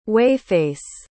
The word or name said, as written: whey-face